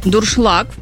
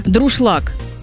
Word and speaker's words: дуршлаг: Юля написала нам, что ее бесит, когда говорят неправильно слово «дуршлаг». «Дуршлаг» правильно, а многие произносят как коверка «друшлаг».